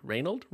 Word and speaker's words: Reynald: R-E-Y-N-A-L-D. Reynold. Reynold. It's Reynold, definitely.